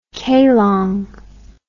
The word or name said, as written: Kelong